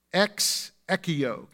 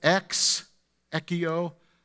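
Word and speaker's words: ex ekio (ἐξηχέω): But just the word ring out is, you pronounce it, ex ekio. Can you say that? Ex ekio.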